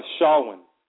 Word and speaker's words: Shawon: Shawon.